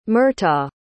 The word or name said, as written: murtagh